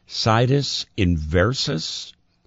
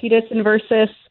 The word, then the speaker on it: situs inversus: But there have been reports that Catherine O'Hara had been living with a condition called, I hope I'm pronouncing this correctly, situs inversus. Did I pronounce that correctly? – You know, I've heard it said a few different ways. I've heard it that way. I've heard situs inversus.